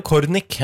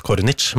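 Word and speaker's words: Cornic: Ja, Leo Kornik er en fyr som er veldig lett å tenke at uttales Kornic, men han er fransk, egentlig. Eller morra, eller... – Morra er fransk. Men så, da går jeg på øret og sier jeg, det er Leo Kornik, ikke Kornic, for eksempel.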